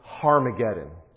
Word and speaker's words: Harmageddon: Okay, and in Greek, there's a rough breathing mark over Armageddon, so it's literally pronounced Harmageddon.